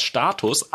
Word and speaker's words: Status: Also richtig, was Noah gesagt hat, ist ja genau richtig, dass sowohl im Singular als auch im Plural heißt das Status. Aber, jetzt steht hier im Duden noch drin, ein Unterschied besteht lediglich in der Aussprache. Im Plural, genau, im Plural wird das U lang ausgesprochen.